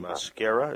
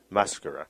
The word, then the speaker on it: Maschera: What, so you play Renato in Un Ballo in Maschera? Is that how you pronounce Maschera.